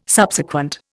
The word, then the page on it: Subsequent: Indian